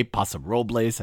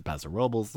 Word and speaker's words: Paso Robles: I didn't say Paso Robles. I said Paso Robles.